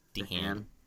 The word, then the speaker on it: DeHaan: How did you pronounce his last name? Dehan. Okay.